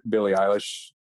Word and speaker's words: Billie Eilish: I mean, I listen to Billie Eilish.